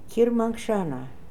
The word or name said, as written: Kirmankshana